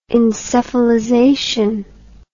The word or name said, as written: encephalization